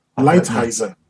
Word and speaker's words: Lighthizer: Lighthizer.